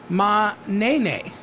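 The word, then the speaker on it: Ma'nene: You should have waited because you could have been a part of today's festivities, which I believe is pronounced, let me get the proper way to pronounce it, Manene, which is M-A hyphen N-E-N-E.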